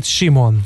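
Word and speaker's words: Simone: Endre, Nina Simon nevének kiejtése az E betű nélkül történik, mint Simon.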